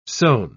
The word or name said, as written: sown